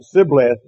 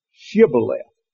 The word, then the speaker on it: Shibboleth: Yeah, they pronounced it Sibboleth, and it's supposed to have been Shibboleth.